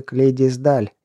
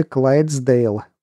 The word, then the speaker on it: Клайдсдейл: «Нет, точно, это лошадка породы Клейдисдаль или Клайдсдейл». По-разному произносят. Видел таких в Глазго.